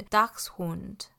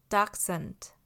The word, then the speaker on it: Dachshund: 德文的话呢，会是 Dachshund 或者是 Dachshund，那英文的话呢，则会是 Dachshund。 Dachshund 真的差蛮多的，然后这个字的意思是腊肠狗，Hund 在德文中是狗的意思，是一个阳性的名词，der Hund。那 Dachshund 这个字呢，在德文里它是腊肠狗的意思，没错。